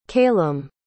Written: caylem

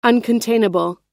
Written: uncontainable

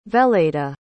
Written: veleda

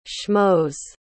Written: schmos

